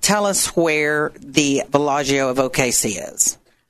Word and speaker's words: Villaggio: Villaggio is spelled VillaGio. – Beautiful. – And that is village in Italian. – Oh, thank you. Villaggio.